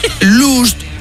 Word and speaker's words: lost: que el garito divierte, inspira y educa, porque vamos a ver, last, L-A-S-T, es... – último. – Lost, L-O-S-T, es... – perdido. – Y lost... que no se pronuncia luz, sino que se dice también lost. Last, pero last, L-U-S-T, significa...